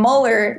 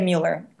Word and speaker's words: Mueller: So Amy is spelled A-M-I-E-E. So I know that's totally weird. A-M-I-E-E. And then Mueller looks like Mueller. M-U-E-L-L-E-R.